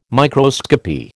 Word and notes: microscopy: American English

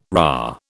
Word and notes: rah: British English

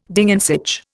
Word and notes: Ding an sich: Indian

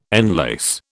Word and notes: enlace: British English

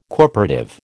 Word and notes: corporative: American English

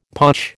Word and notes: potch: American English

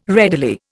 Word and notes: readily: Indian

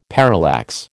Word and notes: parallax: American English

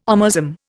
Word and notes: omasum: Indian

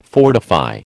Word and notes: fortify: American English

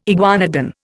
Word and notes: iguanodon: Indian

